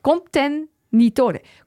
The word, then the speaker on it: contenitore: Coperchio contentitore. Contenitore. Coperchio contenitore.